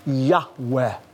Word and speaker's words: Yahweh: And we're not exactly sure how it's pronounced, but Semitic scholars believe it's pronounced a breathy Yahweh.